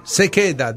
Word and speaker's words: se queda: Intanto come si pronuncia, giusta la pronuncia? È un'espressione. – Puoi parlare? – Se queda, dico. Si dice così in spagnolo, credo, non in portoghese, però.